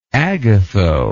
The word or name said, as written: agatho